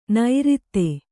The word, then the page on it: ನೈರಿತ್ತೆ: Kannada